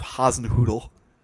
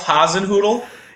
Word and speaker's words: Hasenhüttl: His name is Ralph Hasenhüttl. – That's a fun name to say. Come on. Ralph Hasenhüttl? – Yes. It's spelled H-A-S-E-N-H umlaut T-T-L. – Sounds like Hasenhüttl.